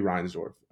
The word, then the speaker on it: Reinsdorf: Jerry Reinsdorf. Or did I say his name right? Yeah, Jerry Reinsdorf? Perfect, perfect.